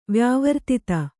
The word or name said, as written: ವ್ಯಾವರ್ತಿತ